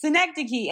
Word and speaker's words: synecdoche: And Charlie Kaufman has to correct him. He goes, it's synecdoche.